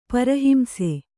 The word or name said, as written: ಪರಹಿಂಸೆ